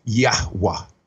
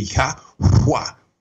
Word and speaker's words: Yahweh: So through the studies, we would see that the Yud and the He, we see as kind of a Yah with the kind of an exhalation. We also know that the Vav, when it's in the middle, takes on a vowel sound, which in the pictorial Hebrew was a nail, which would make a sound in their minds. And so we see the Yahweh, and it almost gives us three syllables, but we can also say it kind of fast down to two.